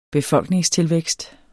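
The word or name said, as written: BEFOLKNINGSTILVÆKST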